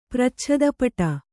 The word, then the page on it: ಪ್ರಚ್ಛದಪಟ: Kannada